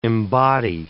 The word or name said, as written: embody